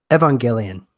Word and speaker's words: Evangelion: And it is official, according to Netflix, the show, and I'm going to always say it wrong, probably Neon Genesis Evangelion? How do you say that? – Evangelion. – Oh, yeah, okay. Yeah, I was so close.